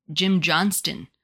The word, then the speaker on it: Jim Johnston: Oh, by the way, before we get too far away from this, a few minutes ago, we were talking about Jim Johnston. I think I said his name wrong. I said Jim Johnson. You heard me, didn't want to correct me, but then you overcompensated by saying Jim Johnstone.